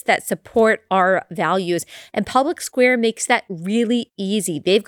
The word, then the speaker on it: Public SQ: All you have to do is download the Public Square app. So wherever you download your apps, just go to the app store, type in Public SQ. It's spelled Public SQ, but it's pronounced Public Square.